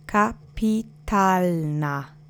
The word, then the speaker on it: kapitalna: The accent is on the penultimate syllable. Let's repeat it together three times. Kapitalna.